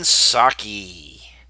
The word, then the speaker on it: Psaki: And that's Jen Psaki, or Psaki, as it's actually spelled.